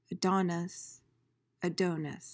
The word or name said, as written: adonis